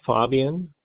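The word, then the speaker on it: Faubion: Yes, it's William Fabian.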